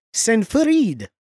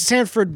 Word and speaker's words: Sanford: Joining at the Squire level, we've got Daniel Sanfarid. – Sanfarid. – It's Sanford, but like, I don't know how to mispronounce Sanford.